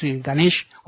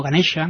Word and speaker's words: Ganesha: que es Ganesh o Ganesha, ¿no? Que es como se pronuncia ahora en hindi.